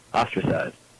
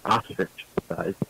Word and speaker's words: ostracized: Not ostrich-sized. Ostracized. Not ostriched. Not, okay, wait, what is it? Ostrich? Ostrich, an ostrich. Is that how you say it? Like the bird thing? Animal? – Ostracized. – Ostrich. You know, an ostrich. – Society. – I'm getting ostracized. – Ostracized. – Ostrich-sized. – Ostracized from society.